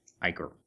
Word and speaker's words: ichor: Ichor, an ethereal fluid flowing in the veins of the gods. An acrid, watery discharge, as from an ulcer or wound. Ichor.